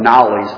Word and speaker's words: Knollys: It's spelled K-N-O-L-L-Y-S. It looks like Knowles, but it's pronounced Knowles.